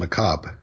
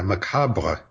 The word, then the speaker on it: macabre: Yeah, and often they don't pronounce the last syllable at all. It's just macabre. – Yeah, it almost comes off as a breath at the end. – Yeah, macabre would be the French pronunciation.